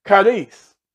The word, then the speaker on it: charis: In the New Testament, grace is derived all but once from the Greek word charis, which is spelled C-H-A-R-I-S, C-H-A-R-I-S.